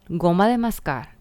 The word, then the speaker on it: goma de mascar: The gum, goma de mascar, that's how you say it.